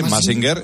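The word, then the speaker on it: Mask Singer: Oye, me tienes que contar, buenas noches, que había un infiltrado en un concurso que se llama Massinger, ¿no? ¿Massinger? ¿Lo digo bien? ¿Massinger? – Sí, Massinger, en Antena 3,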